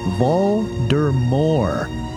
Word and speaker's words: Voldemort: According to Rowling, it is pronounced Voldemort. The T is silent.